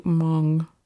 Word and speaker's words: Hmong: There are a lot of H-M-O-N-G, which I'm going to say Hmong,